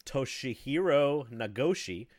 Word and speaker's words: Toshihiro Nagoshi: So, Toshihiro Nagoshi.